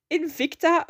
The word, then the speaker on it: Invicta: Then we had, oh, I'm gonna try and pronounce this team correctly because I can never do it. Invicto See, there I go. Invicta Virtuosi Racing with Jack Doohan and Emery Cordell, both not rookies.